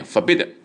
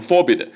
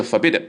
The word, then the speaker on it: forbidden: Forbidden 这个单词原型呢是 forbidden，我在词汇表里面列好了 forbidden， F O R B I D，重音在后面 forbidden 啊，不要念成 forbidden，是 forbidden。这个单词是不规则的动词，所以呢，动词的三式我在括号里也给大家了。